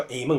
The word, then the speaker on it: amen: 比如说在北美，我们刚刚看到大选之后有一些议员在国会祷告的时候，很有意思，我们通常说祷告是奉主耶稣基督的名求阿们，我们会说阿们，就是 a 加个 man，其实这个是个发音，是希伯来文直接过来的发音，或者希伯来文到希腊文，然后变成英文到中文，我们都叫 amen 或者 amen，对不对？